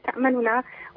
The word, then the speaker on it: تأمننا: في الإشمام في كلمة تأمننا، وهي كأن فيها استشعار لعدم المصداقية كما قال الشيخ بارك الله فيه في قولهم حول أمن يوسف معهم، فلا ننطقها بنون مشددة واضحة بينة، بل فيها ضم الشفتين